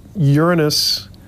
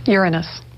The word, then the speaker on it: Uranus: I call it Uranus unless I'm making a joke. – Uranus. – Strictly speaking, it's pronounced Uranus is the pronunciation.